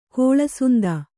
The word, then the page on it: ಕಳ್ಳಸಂತೆ: Kannada